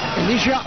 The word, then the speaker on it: Elisha: ¿Está bien dicho Elisha? – Elisha Owusu. – Ahí sí lo dije bien. – Está muy bien.